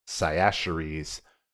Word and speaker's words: Cyaxares: Cyaxares, which I've also heard pronounced Cyaxares, was successful in this endeavor, slaughtering his Scythian overlords and driving the remainder out of their kingdom to hunt and raid elsewhere.